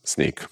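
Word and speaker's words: Snyk: I did not anticipate how many questions we'll get about how do you pronounce sneak. So it is like the shoes, like sneakers, like sneaking out.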